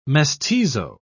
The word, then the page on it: mestizo: British English